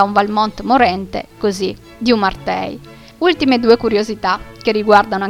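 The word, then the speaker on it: Merteuil: Nel corso di questa sera vi ho parlato della marchesa di Umartei, ma in realtà la pronuncia corretta francese è Mertoie,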